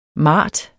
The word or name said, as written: MARTETS